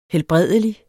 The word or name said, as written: helbredelig